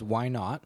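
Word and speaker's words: Whynaught?: So this one, I will pronounce it as why not, just a common phrase or question. It's spelled capital W, then all lowercase H Y N A U G H T, and there's a question mark in the official name.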